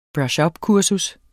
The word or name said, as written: ups